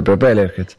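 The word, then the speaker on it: Propellerheads: Pero Propeller Heads. No sé si se pronuncia así. – Sí.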